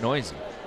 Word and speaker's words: Neuse: Sheldon Noisy, N-E-U-S-E. And I pronounce that Noisy.